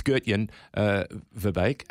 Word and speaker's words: Gertjan Verbeek: His surname is Verbeek, but it's Gertjan Verbeek.